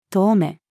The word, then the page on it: 遠目: female